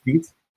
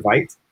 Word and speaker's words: Vite: Apparently it's pronounced Vite. – I prefer Vite, but I don't know.